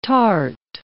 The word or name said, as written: tart